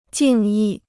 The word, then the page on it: 敬意: jìng yì